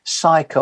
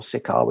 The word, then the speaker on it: Sychar: And verse 5 says this, it says, then he came to the city, to a city rather, of Samaria, which is called Sychar or Sychar, whichever way you want to pronounce it, near to the parcel of ground that Jacob gave to his son, Joseph.